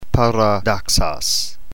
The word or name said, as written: paradoxos